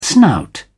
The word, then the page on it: snout: British English